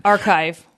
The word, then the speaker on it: arXiv: Archive.